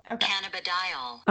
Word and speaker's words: cannabidiol: Cannabidiol.